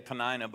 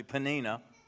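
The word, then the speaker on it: Peninnah: And anybody ever have a panini? That's how you're actually supposed to pronounce the front end of her name. A lot of people say Penina, but it's actually Penina.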